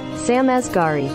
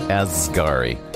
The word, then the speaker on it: Sam Asghari: So, yeah, Britney Spears and her husband, Sam Oshkoshbagosh, Askari? Is that how you pronounce it? I don't know how you pronounce it. Anyway, Yeah, let's see if we got our pronunciation girl. Let's go here to the Google. Let's see how to pronounce. It is our girl. All right, here we go. – Sam Asghari. – Asghari.